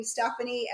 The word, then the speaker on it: Stephene: But my father's name was Stephen, and so they just added, my parents added an E, and I became Stephene, and so it's S-T-E-P-H-E-N-E, and that's how Stephene is spelled for my name.